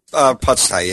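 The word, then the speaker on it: Pusztai: Is it Pustai? Did I say that correctly? – Pustai, yeah.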